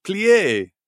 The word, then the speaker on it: Plié: Well, what if I told you it's spelled P-L-E with an accent? Aigu. – Plié.